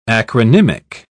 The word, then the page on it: acronymic: British English